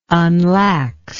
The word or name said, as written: unlax